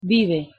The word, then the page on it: vive: Spanish